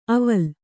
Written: ಅವಲ್

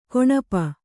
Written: ಕೌಣಪ

